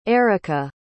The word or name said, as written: erykah